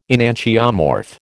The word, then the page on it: enantiomorph: American English